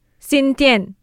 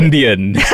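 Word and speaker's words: Xindian: Yeah, so the name of this place is spelled X-I-N. – Sin. – D-I-A-N. – Sin Tian. – Yes. – Right? Or exactly. Zindian.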